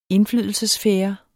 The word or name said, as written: indflydelsessfære